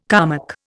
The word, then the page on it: Kalmuck: Indian